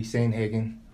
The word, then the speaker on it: Sandhagen: Today we have UFC bantamweight Cory Sandhagen.